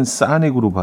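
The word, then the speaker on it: Sonic: 그래서, 썩, 그 크가 끝에 살짝, 썩, 약간 요 정도로 붙어 있고, 소닉은 오는 아 발음을 하는 경우가 많아요. 영국에서 이제 오 발음을 하고, 어, 어, 북미 쪽에서는 오, 아로 발음하기 때문에, 소닉은 사닉으로 발음을 하는 경우가 많은데, 뭐 굳이 이렇게 또 이렇게 뭐, 뭐 굴려야 되나라는 생각도 있지만 예.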